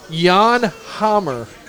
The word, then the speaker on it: Jan Hammer: It has a silent J. Jan Hammer.